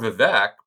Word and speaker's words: Vivek: It was more Vivek. It's like cake. – Vivek the fake. Let's thank the Never Back Down Super PAC for helping us know how to pronounce his first name. – That is how, either way, cake or fake, you know, your mnemonic device is fine.